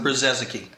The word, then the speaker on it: Brzezicki: How do you pronounce it? – Brzezicki. – Brzezicki.